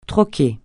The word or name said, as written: troquer